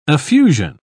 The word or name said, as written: affusion